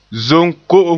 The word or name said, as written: zom ko'om